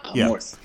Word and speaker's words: Amorth: He plays Father Gabriel Amorth. Am I saying that right? – Yeah, Amorth.